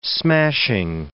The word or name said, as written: smashing